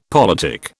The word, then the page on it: politic: British English